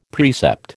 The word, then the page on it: precept: American English